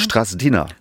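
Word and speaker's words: Strazdiņa: Liva, wie würdest du das aussprechen? Liva ist klar. – Ja, du hast es perfekt ausgesprochen. – Strasdina? – Strasdina. – Strasdina. – Ja.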